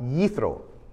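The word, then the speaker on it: Yithro (יתרו): In Hebrew, it's pronounced Yithro.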